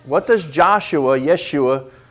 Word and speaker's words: Yeshua: And actually the Jews would not pronounce it Joshua. The way it would be pronounced in the Hebrew is Yeshua. J's were almost always a Y.